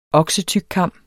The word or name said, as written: oksetykkam